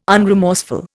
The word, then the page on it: unremorseful: Indian